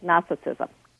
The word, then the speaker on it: Gnosticism: Not so agnostic, Gnosticism. – Yeah, because Gnosticism is spelled G-N-O-S-T-I-C-I-S-M, Gnosticism, or Gnostic.